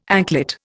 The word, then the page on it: anklet: Indian